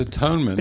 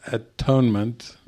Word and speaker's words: atonement: It is not atonement. – No es atonement. – It is at-one-ment.